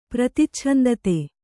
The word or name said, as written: ಪ್ರತಿಚ್ಛಂದತೆ